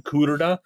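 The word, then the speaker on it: Kudrna: So Ben Kuderna, the high-profile starting pitcher prospect. Kuderna, yep. – Wow, assumed it was Kuderna, you know, like Favre. The R comes after the V, but it's pronounced.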